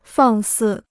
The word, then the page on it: 放肆: fàng sì